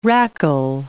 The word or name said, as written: rackle